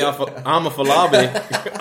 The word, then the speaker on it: Afolabi: My Yemi Afolabi. Hey, Randompedia listeners. I'm Yemi Afolabi.